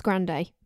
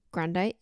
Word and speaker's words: Grande: It's spelled the same as Grande, but it's not Grande. They say Grand, just if you're wondering how to spell it.